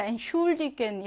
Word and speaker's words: Entschuldigen: 그래서 뭐, 태는요, 그냥 트로 발음하겠죠? 그리고 S체하는요, 슈로 따로 발음하겠지? 이렇게 취가 아니라 트, 슈로 따로 발음합니다. Entschuldigen 이렇게요.